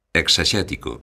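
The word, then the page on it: exexético: Galician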